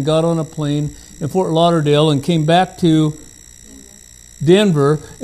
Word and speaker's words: Fort Lauderdale: Fort Lauderdale, Florida. I learned how to pronounce it. It's not Fort Lauderdale, I found out. Fort Lauderdale.